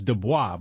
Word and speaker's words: Du Bois: If the motherfucking last name is French, it's Du Bois, motherfucker. I don't hear this Du Bois shit.